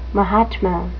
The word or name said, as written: mahatma